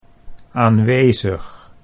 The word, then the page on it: aanwezig: Dutch